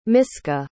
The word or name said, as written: miska